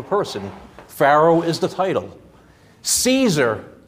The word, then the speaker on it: Caesar: And if you look at the proper, I've been corrected on this, those C's are pronounced with a K. I heard somebody on a podcast someplace, and they were talking about history. They were talking about Cicero. He blurted out Cicero. Like, oh, that's the proper name. Just like Caesar, think of the modern word today called Kaiser.